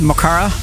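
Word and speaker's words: mo chara: Am I pronouncing it the right way? – If I turn on my Irish accent, Mokara.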